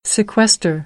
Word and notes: sequester: American English; ɚ

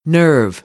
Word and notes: nerve: American English

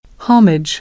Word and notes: homage: American English; ɑː

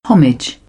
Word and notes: homage: British English; ɒ

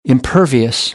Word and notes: impervious: American English; ɝː

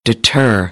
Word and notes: deter: American English; ɝː - ɜː

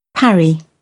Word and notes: parry: British English; æ

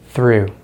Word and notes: through: American English

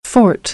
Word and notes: forte: American English; ɔːr - ɔː

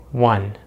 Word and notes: one: American English

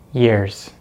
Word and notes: years: American English

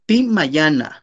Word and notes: timayanaj: Náhuatl

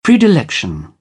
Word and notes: predilection: British English; ɪ

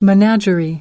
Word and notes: menagerie: American English; ɚ

